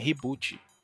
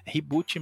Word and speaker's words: Ribut: o tema é reboot, R-I-B-U-T, né, reboot, não, reboot mesmo, como, como se fala.